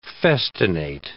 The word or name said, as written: festinate